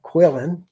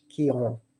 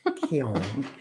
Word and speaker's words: Quillan: And if you're from Texas, you would call it Quillan, like a redneck would say. But we are in France, so we say it like the French, Quillan. Very poetic, kind of sexy, Quillan.